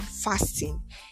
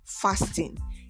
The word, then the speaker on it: fasten: But truthfully, the word is not pronounced fasten. It is pronounced fasten, as in F-A-S-I-N, fasten, not fasting.